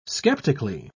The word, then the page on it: sceptically: British English